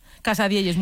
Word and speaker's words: casadielles: ¿Lo digo bien? – Casadielles, muy bien dicho.